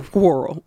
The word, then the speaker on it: whirl: You're pronouncing the H in whirl.